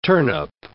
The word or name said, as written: turnip